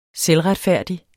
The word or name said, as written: selvretfærdig